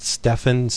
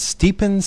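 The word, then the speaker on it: Stjepan: I pronounced it Stefan Sejic, nowhere near the way it's pronounced. It's Stepan Sejic.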